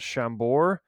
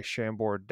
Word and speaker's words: Chambord: And I'm going to butcher the pronunciation, but I think it is called Chambord, with a D at the end. I believe that's French.